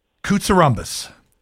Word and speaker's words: Koutsiouroumbas: I'd like to welcome Athan Koutsiouroumbas.